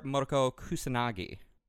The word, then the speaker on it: Motoko Kusanagi: The one and only Major Motoko Kusanagi.